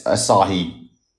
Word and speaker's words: Asahi: Asahi.